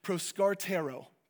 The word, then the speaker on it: proskartero: The word devoted, the word devoting to, is pronounced proskartero.